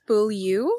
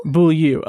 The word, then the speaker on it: Beaulieu: And to bring us into the review, the premise, courtesy of IMDb, is when teenage Priscilla, I can't remember how to pronounce her name, Beaulieu? – Close. I think it's Beaulieu. – Beaulieu.